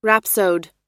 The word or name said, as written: rhapsode